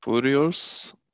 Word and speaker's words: furious: So instead of foo, it's few. Furious. – Furious. – Furious. Yes, exactly. It's kind of like, or like curious, when you curiosity.